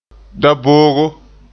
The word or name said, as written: daboogo